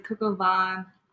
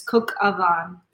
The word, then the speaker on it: coq au vin: I used to say coq au vin all the time. It's like coq au vin. I like to say vin because it's short for vino, but it's coq au vin.